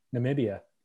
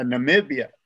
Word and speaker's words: Namibia: Namibia. – Yeah, Namibia.